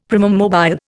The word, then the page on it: primum mobile: Indian